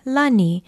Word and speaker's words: lani: lani. It is spelled L-A-N-I, and it means sky, heaven, or heavenly, and can also mean spiritual.